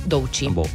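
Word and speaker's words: Doechii: Doici.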